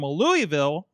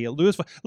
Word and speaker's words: Louisville: I was fresh off of this morning's drive-in from Louisville. I got called out for saying it wrong on a podcast a couple weeks ago. So, and I didn't learn while I was there. – It's Louisville.